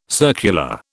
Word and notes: circular: British English

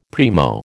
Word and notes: primo: American English

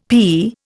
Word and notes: p: Indian

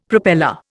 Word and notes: propeller: Indian

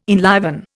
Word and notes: enliven: Indian

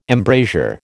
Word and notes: embrasure: American English

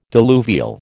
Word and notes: diluvial: American English

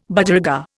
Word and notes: Budgerigar: Indian

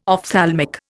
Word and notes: ophthalmic: Indian

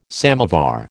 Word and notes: samovar: American English

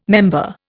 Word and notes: member: Indian

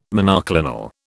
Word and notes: monoclonal: British English

